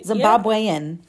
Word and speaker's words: Zimbabwean: Is that the right way to say that? – That is right. – Zimbabwean, yeah.